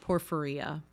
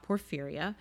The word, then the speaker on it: porphyria: I remember in school I would always pronounce it porphyria, but that's not it. It's a porphyria.